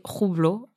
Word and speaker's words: Hublot: Et il lui propose une montre de la marque Hublot, prononcée Roublo en russe et on lui dit que c'est la marque que porte Poutine.